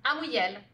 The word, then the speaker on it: Amouyel: Amouyel.